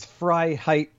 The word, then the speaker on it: Freiheit: David Freiheit, how are you? Am I pronouncing that right? – Yes, it is. It's Frei height phonetically, unless we're in French and we roll the R.